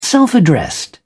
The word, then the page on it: self addressed: British English